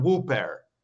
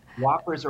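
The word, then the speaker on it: Whopper: Because we're like, Dad, Whoppers are at Burger King and it's not pronounced Whooper.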